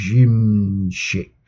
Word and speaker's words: Szymczyk: It was produced, he says, scrolling through the screen, produced by Glyn Johns and Bill Zymczyk. I'm really sorry if I'm pronouncing that wrong. Szymczyk. Zymczyk. There you go.